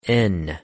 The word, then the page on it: n: English